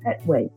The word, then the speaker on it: Petway: T-A-L-I-B like baby, A-H. And my last name is Petway. P like Peter, E, T like Tom, W-A-Y.